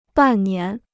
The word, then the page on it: 半年: bàn nián